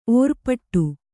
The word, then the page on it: ಒರ್ಪಿಡಿ: orpiḍi